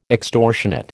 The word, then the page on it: extortionate: American English